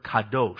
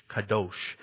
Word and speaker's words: qadosh: Now, in Hebrew, the word there for holy is the word kadosh. It's spelled Q-A-D-O-S-H, kadosh.